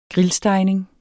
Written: grillstegning

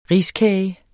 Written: riskage

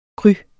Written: cru

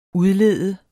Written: udlede

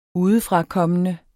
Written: udefrakommende

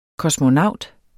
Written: KOSMONAUTEN

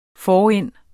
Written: forind

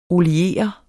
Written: oliere

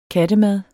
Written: kattemad

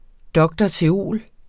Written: dr.theol.

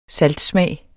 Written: saltsmag

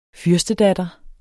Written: fyrstedatter

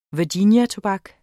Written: virginiatobak

